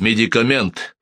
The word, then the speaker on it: медикамент: «Потому что говорится «медикамент» с ударением на последнем слоге.